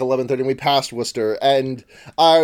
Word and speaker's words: Worcester: How do you say Worcester? – Worcester. – Yeah. Absolutely. All those extra letters are just for nothing.